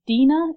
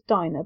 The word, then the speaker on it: Dinah: and the fact that it was I think Dina, is that how the name is pronounced? – I always thought it was Dinah, but I couldn't. Dinah, you might be right. Dinah it might be.